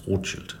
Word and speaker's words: Rothschild: Here in Vienna it's Rothschild. – Rothschild, yeah, we say Rothschild. – As in, yes. Frankfurt. – Yes, yes, the German pronunciation.